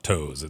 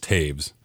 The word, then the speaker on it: Toews: Well, and Toews is not Toews. It's I believe it's Taves.